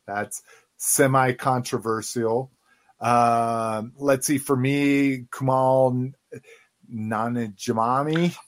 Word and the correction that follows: Kumail Nanjiani: Pronounce it correctly for us, Cliff, because I murdered it. I know. Oh, Kumail Nanjiani. Yes, much better.